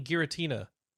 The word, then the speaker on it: Giratina: Oh, Durant. You mean Giratina? – Oh, Giratina. – Is that how? I didn't. – Maybe that's not how you say it. Is it Garanta? – I have I.